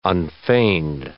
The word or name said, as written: unfeigned